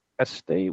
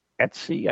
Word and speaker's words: Etsy: If you go check it out on Estee, which is actually Etsy, I guess. I've been saying it wrong all these years, but there's a surprise to no one.